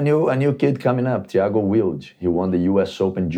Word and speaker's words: Wilde: Well, let's say Wild, W-I-L-D. – Wild, you say? Yeah. – So we say Wilde. – Wilde. – There you go. – It's Wild. Wilde.